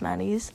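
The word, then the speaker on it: Mady: Our Instagram is Maddie and Kelsey. I'll spell it out because my name is spelled differently than most Maddies. It's M-A-D-Y and Kelsey.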